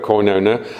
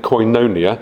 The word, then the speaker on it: koinonia: I'm going to keep using that Greek word, koinonia, because it's a very good word, especially if I pronounce it right, which I didn't, koinonia.